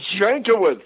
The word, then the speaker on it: Jankiewicz: It is Pat Jankowicz. – Jankowicz. That's what I thought. I just wanted to make sure. – Yeah. That's the Americanized. I guess they call it Yankovic in the old country, but that's too much like that stupid comedian.